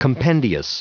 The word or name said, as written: compendious